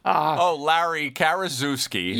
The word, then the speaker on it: Larry Karaszewski: Larry Karaszewski.